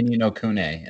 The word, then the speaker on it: Ni no Kuni: I say Ni No Kuni, and that's the right way.